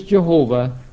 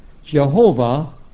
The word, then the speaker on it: Jehovah: So here they tell us that Jehovah, pronounced as Jehovah in Latin because the J is like a Y and the V is like a W in Latin, is a false reading of the Hebrew Yahweh.